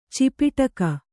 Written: ಚಪೇಟಕ

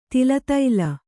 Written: ತಿಲತೈಲ

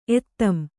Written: ಎತ್ತಂ

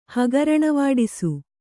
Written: ಹಗರಣವಾಡಿಸು

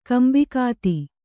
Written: ಕೊಂಬುಗತ್ತಿ

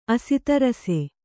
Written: ಆಸ್ತರಿಸು

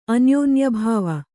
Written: ಅನ್ಯೋನ್ಯಾಭಾವ

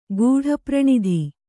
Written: ಗೂಢಪ್ರಣಿಧಿ

